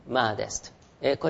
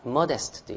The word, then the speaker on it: modest: はい、このマーデスティの、形容詞形が、マーデストですね、マーデスト、 M-O-D-E-S-T ですね、 M-O-D-E-S-T、マーデスト。これが控えめなという意味ですね。はい、マーデスト、あるいはモデストというね、読み方、イギリス人の先生なんかはね、モデストと読みますよね。